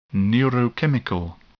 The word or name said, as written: neurochemical